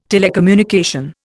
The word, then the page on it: telecommunication: Indian